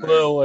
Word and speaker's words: Lille: Other one, Ajax versus Lille. Is that how you pronounce that team's name? – Lille, I think, yeah, but yeah.